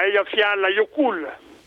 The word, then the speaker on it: Eyjafjallajökull: Con pronuncia italiana, Eyjafjallajökull. – Ah, vabbè, no, va bene, sì, certo. Non le chiedevo la pronuncia islandese, insomma, va benissimo così, però almeno gli date dignità di nome, meno male.